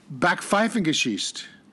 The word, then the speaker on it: Backpfeifengesicht: Backpfeifengesicht. – Backpfeifenisch. – No, backpfeifengesicht. – Backpfeifengesicht. – That's it.